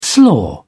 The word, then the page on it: slaw: British English